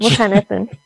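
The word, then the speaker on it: Mukhannathun: Muhannathun. Now, you say it properly. Thank you very much. – Muhannathun, yeah.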